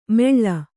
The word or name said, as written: ಮೆಳ್ಳ